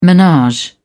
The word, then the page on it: menage: British English